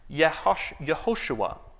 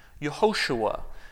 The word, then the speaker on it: Yehoshua: The name that in Hebrew is pronounced something like Yehoshua. Yehoshua, which came to be known as Joshua in English.